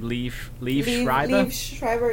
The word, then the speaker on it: Liev Schreiber: Liev Schreiber, yeah.